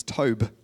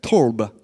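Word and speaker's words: torb (טוב): And so the word good there in Hebrew, which is, I got the pronunciation, is tob. No, it's like torb.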